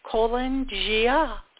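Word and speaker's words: Calan Gaeaf: Then there is Colan Gia, and that's spelled C-A-L-A-N, then G-A-E-A-F. So Colan Gia is Welsh, celebrated the first day of winter, denoting this new beginning as Samhain, November 1st.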